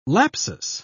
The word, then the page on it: lapsus: British English